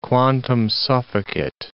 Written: quantum sufficit

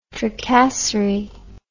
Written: tracasserie

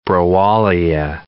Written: browallia

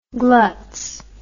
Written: glutz

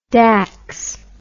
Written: daks

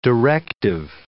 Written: directive

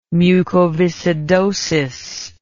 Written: mucoviscidosis

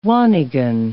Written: wannigan

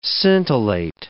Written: scintillate

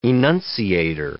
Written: enunciator

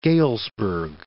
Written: Galesburg

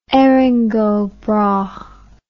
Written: Erin go bragh

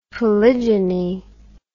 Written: polygeny

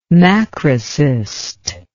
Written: macrocyst

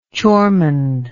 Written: choreman

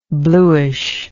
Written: bluishness

